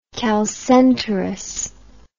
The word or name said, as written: chalcenterous